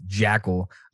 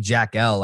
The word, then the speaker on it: Jackal: So uh, that being said, if you missed our last interview with Janny Jackal, I was saying Jack L. Like I caught myself throughout the we we discussed that.